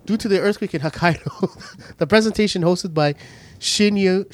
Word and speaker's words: Hokkaido: Following last week's Nintendo Direct delay due to the earthquake in Okiohio. How do you pronounce that? – Let's do this. – I can't pronounce it. Hokkaido.